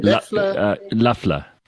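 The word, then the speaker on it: Loeffler: Apparently this Luffler, how do you pronounce her name? – Luffler. Kelly Luffler. Yeah.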